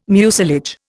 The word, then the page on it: mucilage: Indian